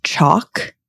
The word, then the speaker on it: Chalk: My maiden name is Chalk, like chalkboard. – Yeah. – And people used to just go wild with what they would pronounce it as. And I'd be like, it's just like sidewalk chalk.